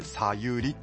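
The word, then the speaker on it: 茶湯里: 相良村、はい、まずは相良温泉さゆり。お茶の茶に、うん、お湯の湯に里と書いてさゆりっていうんですね。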